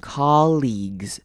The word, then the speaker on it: colleagues: 好，接下来看到中间 ea 念长母音 e 的声音 colleagues， ea 念长母音 e。接下来 gue 呢只会念 g 的声音，后面 ue 是不发音的 colleagues，等于说在 g 后面直接念 s 的声音，gs 的声音 colleagues。